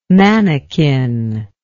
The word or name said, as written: manakin